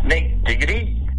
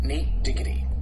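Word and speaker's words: Nate Digad: I'm here to tell you the way it's supposed to be pronounced. It's called Nate Diggity. See what I did? See? Nate Diggity. D-I-G-A-D. Nate D-I-G-A-D. Diggity. Very clear, right?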